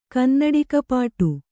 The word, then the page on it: ಕನ್ನಡಿಕಪಾಟು: kannada